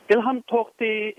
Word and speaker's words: Ilham Tohti: Ilham Tohti, if I'm pronouncing his name correctly? – Yes, you are correct. Ilham Tohti is one of the best and brightest Uyghur scholars, who was a professor at the China's Nationalities University in Beijing.